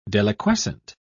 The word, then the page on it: deliquescent: British English